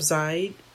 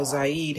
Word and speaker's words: Zayd: So this police officer decided to stop Zayd Atkinson. Hope I'm pronouncing his name correctly. Z-A-Y-D is how he spells his first name.